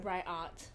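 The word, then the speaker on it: Ryat: Riot.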